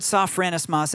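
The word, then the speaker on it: Sophronismos (σωφρονισμός): And that Greek word that's translated sometimes discipline, sometimes sound mind, it's the Greek word sophronismos. And that's actually the correct pronunciation. I looked it up.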